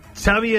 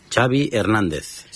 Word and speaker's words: Xavi Hernández: What is the correct pronunciation of your first and last name? – Xavi Hernández. – Xavi. – Okay. Yeah, Xavi like chocolate.